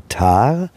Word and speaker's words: Thar: Zum einen die große indische Wüste, die Wüste Thar, spricht man das aus? – Die Wüste Thar, ja.